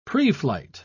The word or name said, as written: preflight